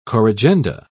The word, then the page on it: corrigenda: British English